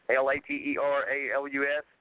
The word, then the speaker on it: Lateralus: And I know I'm going to kill the pronunciation here, but Lateralis. Lateralus, and the chorus for that is Fibonacci.